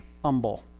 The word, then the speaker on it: humble: And the word is humble, but not where I grew up, okay? Silent H, humble.